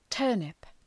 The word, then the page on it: turnip: ‘tɜ:rnıp